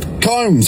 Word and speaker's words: Combs: Combs, apparently. I need you to jump in here and settle this because I am being told that I pronounced your name wrong. And it's Luke Combs, not Luke Coombs.